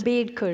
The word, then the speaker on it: Ambedkar: How do you pronounce Ambedkar.